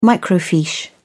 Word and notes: microfiche: British English

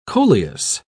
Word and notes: coleus: British English

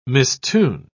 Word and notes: mistune: British English

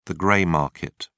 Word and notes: gray market: British English

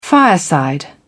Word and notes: fireside: British English

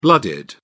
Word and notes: blooded: British English

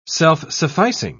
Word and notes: self-sufficing: British English

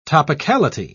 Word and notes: topicality: British English

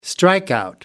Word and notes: strikeout: American English

